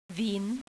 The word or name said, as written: Wien